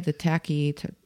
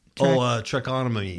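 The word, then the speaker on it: tracheotomy: And then he has one of those, how do you say it? The tachy. – Trachotomy.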